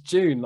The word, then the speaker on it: Dune: My brother had an argument with my family saying that it's Dune. And we were like, yeah, but it's June, like in an English accent. He's like, yeah, but it's spelled Dune. And I was like, yeah, but you don't say YouTube, do you? You say YouTube. He's like, okay, yeah, that's a good point. June.